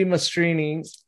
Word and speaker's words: Mastrine: And today our guest is our new friend, Julie Mastrine. Is that the proper pronunciation? I'm sorry. – No, it's Mastrini. It's Italian. – Okay, oh, cool. I'm Italian too, so I should have known that. Julie Mastrini, and I heard you first on Counterflow with Buck Johnson, and I loved the interview.